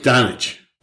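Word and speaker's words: Dončić: Is it Doncic? – Doncic.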